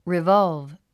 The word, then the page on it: revolve: rivάlv; リバァゥブ